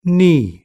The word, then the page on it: knee: British English